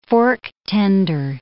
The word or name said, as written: fork-tender